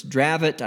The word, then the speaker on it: Dravet: So that would be then why, once again, that outside of that very narrow scope of particular, and it's Dravet, I think is how you pronounce it, D R A V E T.